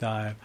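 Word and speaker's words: Dàimh: It's spelled like D-A-I-M-H, but it's pronounced Dive.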